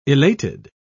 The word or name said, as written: elated